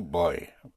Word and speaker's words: voy: I am going, in Spanish, is boy. Oh, boy. Boy, oh, boy, oh, boy. Boy, boy. Spelled V-O-Y. Boy, yes, I'm going. Boy. Boy. Again, the V, pronounced with your lips, like a B.